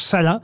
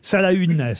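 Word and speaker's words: Salaün: Salin, pour ceux qui l'ignoreraient, ça s'écrit S-A-L-A-U-N, avec un tréma sur le U, mais ça se prononce Salin. Salahune, si vous préférez.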